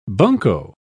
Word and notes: bunko: British English

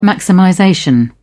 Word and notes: maximization: British English

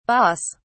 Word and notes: baas: British English

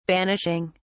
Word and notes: banishing: British English

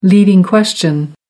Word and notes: leading question: American English

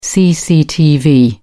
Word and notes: cctv: British English

